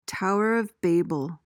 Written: tower of babel

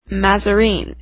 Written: mazarine

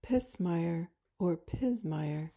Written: pismire

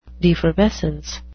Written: defervescence

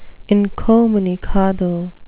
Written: incommunicado

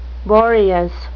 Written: boreas

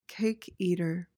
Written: cake eater

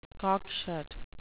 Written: cockshut

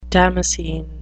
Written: damascene

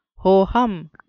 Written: ho-hum

